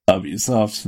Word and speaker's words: Ubisoft: Ubisoft. – Ubisoft, that's right.